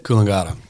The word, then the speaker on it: Coolangatta: Coolangatta.